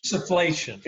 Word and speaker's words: insufflation: And then so I can take this little inflation bag. Am I saying that right? – Insufflation. – Insufflation, thank you.